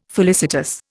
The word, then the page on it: felicitous: Indian